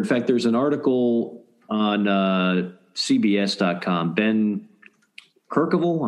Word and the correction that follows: Kercheval: I hope I'm pronouncing that right. Yes, Kercheval. Some Kercheval. – There we go.